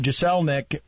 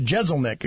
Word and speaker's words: Jeselnik: We were talking about Anthony Jeselnik, and I pronounced his name wrong again. I do that a lot. Jeselnik, excuse me.